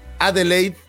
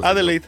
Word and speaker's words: Adelaide: Para 2008 deja los despachos mexicanos de diseño y se muda a la ciudad de Al Adelaide. ¿Cómo se, cómo se pronuncia, por favor? – Adelaide, Adelaide, así.